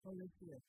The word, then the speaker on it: Coliseu: It's called Colisius, which is C-O-L-I-S-E-U.